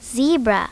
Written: zebra

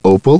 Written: Opal

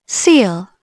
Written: seal

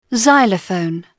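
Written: xylophone

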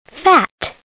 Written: fat

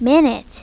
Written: minute

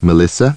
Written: Melissa

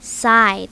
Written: side

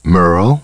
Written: Merle

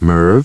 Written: Merv

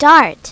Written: dart

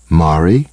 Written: Mari

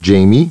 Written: Jamie

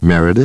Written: Meredith

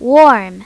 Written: warm

